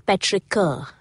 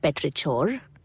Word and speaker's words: petrichor: You know the word I'm talking about, but you're probably saying it wrong. It's petrichor, not petrichor. Petrichor.